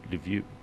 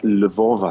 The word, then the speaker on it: Lviv: But apparently, you have to pronounce it Lviv. Lviv. That sounds French. – Lviv. Correct. Lviv. And even Lviv is very, very controversial because there is a Russian pronunciation which is more like Lvov, I think.